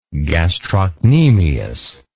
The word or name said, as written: gastrocnemian